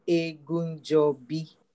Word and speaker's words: Egunjobi: And then the last name is Egunjobi.